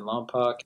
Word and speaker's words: Lompoc: Well, we're starting off in Los Angeles, then we're going to be in Wompoc, if I'm saying that correctly. – Yeah. – September 7th, Fresno, Los Angeles. Yep. – Yeah, September 7th, and then the 8th in Lompoc,